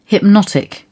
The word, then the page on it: hypnotic: British English